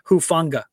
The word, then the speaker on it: Hufanga: And again, the pronunciation, the last name is Hufanga.